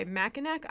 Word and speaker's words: Mackinac: Okay, Mackinac Island is pronounced Mackinac. Okay, Mackinac is so much better.